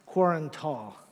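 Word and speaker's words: Qarantal: There is this rugged, barren mountain that is known today as Mount Quatral, Karan Twal. I said that wrong twice in a row. The third time I'm going to get it. Mount Karan Twal. I said it right that time.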